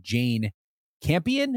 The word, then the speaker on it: Jane Campion: But this movie was released specifically on Netflix, directed by Jane Campion. I'm going to say that's how you say her name. – Yeah, I would say so.